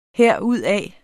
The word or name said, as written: herudaf